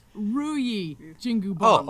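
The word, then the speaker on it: Ruyi Jingu Bang: I'm sorry, I mispronounced the stick's name. It's Ruyi Jingu Bong.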